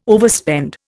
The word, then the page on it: overspend: Indian